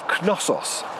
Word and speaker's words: Knossos: It's the Palace of Knossos. Now, I don't know if you don't pronounce the K. – Knossos. – It's K-N-O-S-S-O-S. – So it could be Knossos. – Yes.